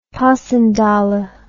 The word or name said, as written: passchendaele